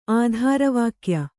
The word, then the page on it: ಆಧಾರವಾಕ್ಯ: ādhāra vākya